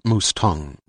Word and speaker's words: Mustang: Like most people, King pronounced the name as he would the horse. – It's pronounced Mustang, Remy replied.